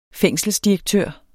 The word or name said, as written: fængselsdirektør